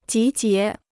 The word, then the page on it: 集结: jí jié